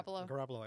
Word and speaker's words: Garoppolo: Jimmy Garoppolo. Did I get that right? – Garoppolo.